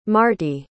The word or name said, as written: marty